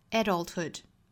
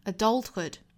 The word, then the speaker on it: adulthood: 应该是 claim 干脆利落的一个音节的词 claim your adulthood 这个词呢是由两种发音的，英式发法会把重读放到第一个音节上 adulthood 美式发法呢重读会在第二个音节 adulthood